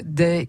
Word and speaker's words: Dey: Day, ça s'écrit D-E-Y, hein, c'est Audrey, d'accord. Donc Day.